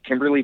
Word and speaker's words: Kymberly: It's Kimberly Byers, and it's Kimberly with a couple of Y's.